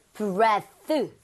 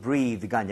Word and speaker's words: breath: Breath. – 어디까지? – 이거까지입니다. – 예, 예. 이가 안 붙고요. – Breath. – 발음도 breathe가 아니라 breath. – Breath. – Breath. 이거 두 개는 주의하셔야 되겠죠? – 네.